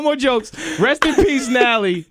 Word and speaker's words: Nally: To Nally. – No, no T. Just Nally. – Oh, it's Nally. – Nally. N-A-L-L-Y.